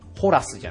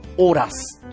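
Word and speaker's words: Horace: HORAS と書くんですけれども、ホラスじゃなくてオラス、ね。 – はい。 H で始まることは全部無音になるので。 – あ、 H 発音しない。 – 発音はしません。 – ということで、オラスという発音になります。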